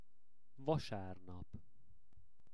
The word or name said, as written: vasarnap